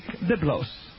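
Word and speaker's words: Biblos (βίβλος): From this book, the Bible is a Greek word meaning in the Greek, the book, pronounced in Greek, Biblos.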